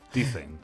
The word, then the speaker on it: Tizen: Tizen.